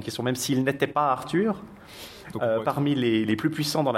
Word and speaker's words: Arthur: Et on nous dit « Caer keni beif Arthur » Arthur, donc le U se prononce I en gallois.